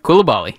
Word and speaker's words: Coulibaly: Most likely going to be Bilal Coulibaly. – Close. – Whatever the hell his name is. How do you say it? – Coulibaly.